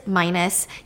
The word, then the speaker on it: Minos: Yeah, I thought his name was pronounced Minos because that's how it's spelled, but it's Minos.